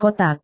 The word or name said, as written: kotak